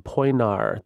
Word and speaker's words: Poenari: It's called Poinar. The I at the end is silent. – So P-O-E-N-A-R-I.